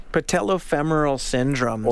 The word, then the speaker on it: patellofemoral syndrome: Patellofemoral syndrome.